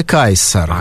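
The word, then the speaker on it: Caesar: Ну да, и современные итальянцы произносят это имя как Чезаре, да, Рим Римляне, видимо, произносили «кайсар».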